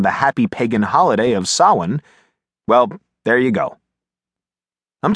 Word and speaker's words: Samhain: To retaliate, my dad started calling me Sam, since he said Sawin, which is how S-A-M-H-A-I-N is pronounced, sounded funny.